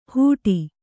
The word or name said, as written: ಹುಡಿ